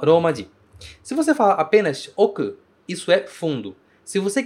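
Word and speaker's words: oku (奥): A pronúncia deste kanji é oku.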